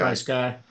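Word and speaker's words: FrSky: Well, that's a great lead in, because the other system that we all talked about was FR Sky. Am I saying that right? FR Sky or Fry Sky? Is it FR Sky? – Fry Sky.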